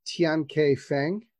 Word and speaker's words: Tiankai Feng: Tian K Feng.